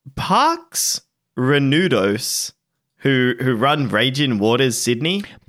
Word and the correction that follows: Parques Reunidos: Parques Reunidos. As I watched a video so I could pronounce it right for one of my recent videos. – Well, you Google how to pronounce something, it's like Parques Reunidos.